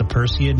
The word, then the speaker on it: Perseid: It's the Perseid meteor showers.